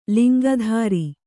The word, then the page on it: ಲಿಂಗಧರ: Kannada